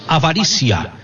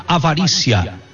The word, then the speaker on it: Avaritia: Ele está falando da operação, se escreve, gente, Avaritia, mas se diz Avaricia. Se escreve Avaritia, mas se pronuncia Avarícia.